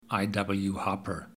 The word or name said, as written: I.W. Harper